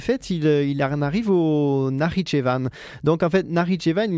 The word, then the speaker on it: Nakhitchevan: Donc nous sommes maintenant au Nakhitchevan, si je prononce bien, excusez-moi. – Oui, Nakhitchevan, oui.